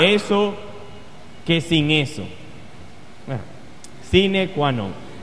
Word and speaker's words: sine qua non: No es así que se pronuncia. Sine qua non.